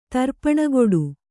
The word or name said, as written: ತರ್ಪಣಗೊಡು